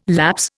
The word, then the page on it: lapse: Indian